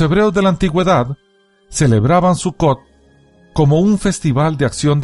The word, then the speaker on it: Sukkot: Sukkot, pronunciado Sukkot o Sukkot, es un festival judío que comienza en el día 15 del mes hebreo de Tirri, aproximadamente entre septiembre y octubre.